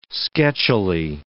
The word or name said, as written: sketchily